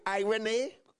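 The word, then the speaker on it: irene (εἰρήνη): It comes from a Greek word. You could pronounce this irene, which means to join and to set at one again.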